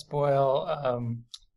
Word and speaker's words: Boyle: Lucas Boyle.